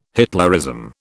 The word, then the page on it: Hitlerism: British English